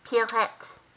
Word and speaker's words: Pierrette: Pierrette.